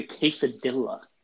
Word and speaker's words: quesadilla: As he said it before, folks, that's not how you say it. It's a quesadilla. Okay? It's a freaking quesadilla. That's how you're supposed to say it, okay? – Quesadilla.